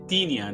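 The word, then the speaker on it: Tinja: O Hatching, cara, ele acompanha a Tindia, Tin, Tin, se pronuncia Tinia, né? Um nome finlandês, uma pré-adolescente tímida, solitária, que vive com a família ali em uma comunidade de aparências de uma aparência idêntica, né?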